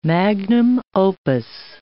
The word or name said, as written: magnum opus